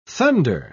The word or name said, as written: thunder